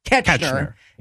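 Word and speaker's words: Koechner: David Kocher, is that how that name's pronounced? – Ketchner.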